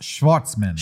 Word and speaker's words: Schwartzman: It's pronounced Schwartzman. – Schwartz. – Like Jason Schwartzman.